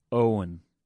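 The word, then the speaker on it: Eoghan: Okay, Ewan. And, no, it's Owen. Oh, just like Owen.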